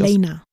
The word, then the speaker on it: Alena: Was man allerdings noch sagen kann, ist, dass Daisy und äh, Elena, jetzt habe ich es wieder falsch ausgesprochen, oder? Wie wird sie richtig? Alena. – Alena. Dass Daisy Alena.